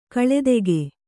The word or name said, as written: ಕಲಾದಕ